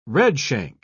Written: redshank

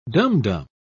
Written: dumdum

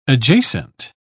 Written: adjacent

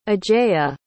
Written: ajeya

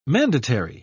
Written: mandatary

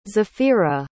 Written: zafirah

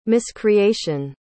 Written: miscreation

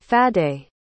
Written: Faddei

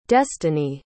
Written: destanee

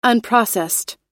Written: unprocessed

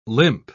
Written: limp

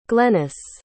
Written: glenys

